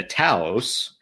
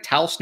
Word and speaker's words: Taos: And so this year, Volkswagen introduced the one that it's going to bring into the U.S., namely the Taos. Am I saying that right? Taos, New Mexico? Isn't that the correct pronunciation? – It doesn't matter because I don't like it either. – Taos.